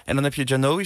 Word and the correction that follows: Jokowi: zeg ik het goed? – Jokowi.